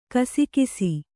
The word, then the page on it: ಗುಸುಗುಸು: gusugusu